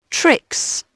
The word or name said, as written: tricks